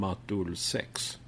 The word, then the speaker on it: Matolsex: Det är ju så konstigt att jag har inte döpt detta namnet själv, utan på Spotify så heter jag M-A-T-O-L-S-E-X, vilket man då kan uttala som Matulsex.